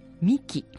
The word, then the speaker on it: 御神酒: まして、はい、そちらでね、沖縄に行かれた方はね、聞いたことがあるかもしれないんですけど、向こうのね、琉球の、古代から受け継がれてる、発酵飲料で、ミキっていう飲み物があるんですね。 – ミキ？ – はい。これは、お米から作られる御神酒です。神様のお酒と書いて御神酒です、